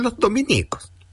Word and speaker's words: Dominicos: Si se alude a los religiosos de la Orden de Santo Domingo, se escribe Dominico, sin tilde en ninguna I. Por lo tanto, es la estación Los Dominicos, la que queda en Las Condes.